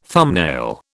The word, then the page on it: thumbnail: British English